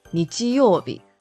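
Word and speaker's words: 日曜日: A word that we can use with Nichi is Nichiyobi.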